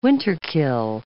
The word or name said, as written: winter-kill